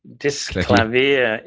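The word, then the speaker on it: Disklavier: The Yamaha Disc Lavier. Criticize my pronunciation. – Well, no, I would pronounce it that way because I fall naturally to the French way of saying it. But Disc Lavier is the more German way of saying it, which is where it comes from, I think.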